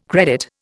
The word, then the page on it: credit: Indian